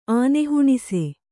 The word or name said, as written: ಆನೆಹುಣಿಸೆ